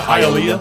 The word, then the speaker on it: Hialeah: Yeah, Hialeah.